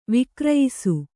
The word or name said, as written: ವಿಕ್ರಯಿಸು